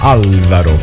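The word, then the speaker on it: Álvaro: De Luna su Alvaro De Luna, anzi l'accento sulla prima A, Alvaro.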